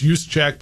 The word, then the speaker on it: Juszczyk: Juice, yeah, it's Juszczyk, but it's spelled J-U-S-Z-C-Z-Y-K.